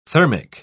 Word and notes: thermic: British English

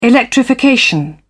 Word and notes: electrification: British English

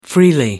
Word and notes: freely: American English

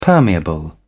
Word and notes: permeable: British English

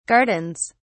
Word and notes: gardens: British English

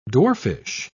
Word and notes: dwarfish: British English

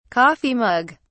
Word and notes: coffee mug: British English

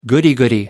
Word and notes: goody goody: American English

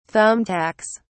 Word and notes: thumbtacks: British English

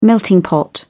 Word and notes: melting pot: British English

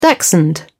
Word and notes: dachshund: British English

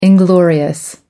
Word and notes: inglorious: American English

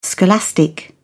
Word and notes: scholastic: British English

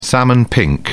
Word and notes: salmon pink: British English

